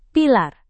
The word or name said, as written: pilar